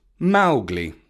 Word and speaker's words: Mowgli: In the Disney film, his name is pronounced Mowgli, as in the name Mo. But Kipling leaves us a note to say, Mowgli is a name I made up. It does not mean frog in any language that I know of. It is pronounced Mowgli, accent on the Mow, which rhymes with cow.